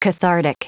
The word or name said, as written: cathartic